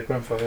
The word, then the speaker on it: Grenfell: I'm saying it right, Grenfell, yeah? – Yeah, Grenfell, yeah.